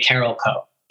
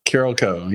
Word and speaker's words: Carolco: It's probably, is it Carolco or is it Carolco? – Everybody in the building used to say Carolco. – Carolco, yeah. – But I've heard it in documentaries called Carolco.